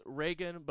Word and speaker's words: Raygun: Was that Raygun or Raygun? Like Reagan? – It's Reagan, but it's pronounced, or it's spelled R-A-Y-G-U-N.